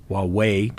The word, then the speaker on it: Wawawai: He drove throughout the area south of Pullman, Washington, west of Moscow, Idaho, including the Wawaway, W-A-W-A-W-A-I Park, common pronunciation, partial corroboration.